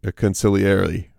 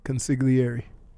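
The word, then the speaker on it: consigliere: Conciliary. – What are you, Tony Soprano? – Yeah. The G silent. – No, it's not. – It's like lasagna. – Why you got to say it like that? – He's right, though.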